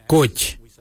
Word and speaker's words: goodj: Garli is spelled G-I-L-Y-I, garli. For the traditional stone axe, we say gotch, gotch. Gotch is spelled G-O-O-D-J, gotch.